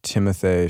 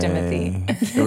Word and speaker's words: Timothée: Instead of Timothy. – It was like kind of like lazy, and it was like Timothée because the accent is on that. on le premier A.